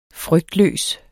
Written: frygtløs

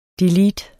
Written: del

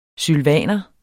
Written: sylvaner

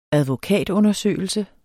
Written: advokatundersøgelse

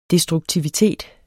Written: destruktivitet

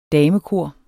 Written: damekor

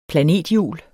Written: planethjul

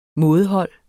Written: mådehold

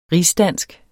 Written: rigsdansk

